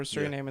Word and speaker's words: Suriname: Suriname? Yeah. Is that how you say it? – Yeah, in South America. – Yeah, they tried to take over Suriname in South America.